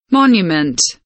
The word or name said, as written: monument